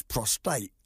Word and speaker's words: prostate: And you're saying it wrong. It's prostate.